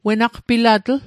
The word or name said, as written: wenaqpilatl